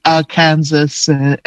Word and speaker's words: Arkansas: I still trip up every time I see Arkansas and knowing like it's supposed to be pronounced Arkansas.